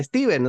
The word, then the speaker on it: Stephen: Y muchos, muchos youtubers o gente que hace reseña de libros y demás le dicen Steven, o sea, es correcto. Eh, es la pronunciación, digamos, natal de él, si se quiere.